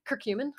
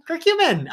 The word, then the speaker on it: Curcumin: Curcumin. – Curcumin.